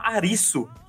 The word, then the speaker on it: Arisu: O, e Cassino tem o personagem principal, ele chama Arisu, que é como os japoneses pronunciam Alice.